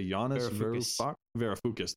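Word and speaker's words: Yanis Varoufakis: I want to say Yannis Varoufakis.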